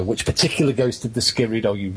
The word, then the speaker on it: Skirrid: This is uh, in regards to the Skidmore, Skidrid. How do you say that? – Skidrid. Skidrid. – Skidrid in. Skidrid in. – Yes, I've been to the Skirrid Inn.